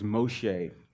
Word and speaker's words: Moshe: The Hebrew pronunciation is Moshe, because she said, "I drew him out of the water."